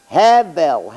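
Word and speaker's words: Hevel (הבל): How do you say Abel in Hebrew? Hevel, Hevel, Hevel.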